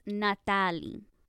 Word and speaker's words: Nataly: I mean, okay, my name is supposed to be pronounced Natalie.